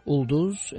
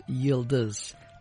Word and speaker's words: Yıldız: In Azerbaijan, they drop the Y and they say Ulduz. In Siberia, it becomes Childuz, and in Turkey, it's pronounced Yıldız, all meaning star and a popular lady's name.